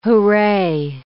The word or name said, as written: hooray